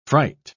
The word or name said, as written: fright